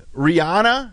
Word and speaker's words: Rihanna: Rihanna, did I say it right? – Yes.